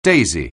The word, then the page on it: daisy: anglais